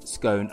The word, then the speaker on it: scone: pronounce scone as scone, as in as gone, or scone as in or, or scone as in scone.